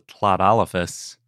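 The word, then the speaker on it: Tlatolophus: I think the coolest hadrosaur we talked about this year was in episode 340, and that was Tlodolophus. – Oh, yeah. Good job remembering how to pronounce that. – Yeah, the lateral fricative, just like Welsh.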